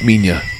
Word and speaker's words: Minilla: But it's pronounced Minya. Well, that's the American pronunciation. The American dub.